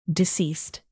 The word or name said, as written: deceased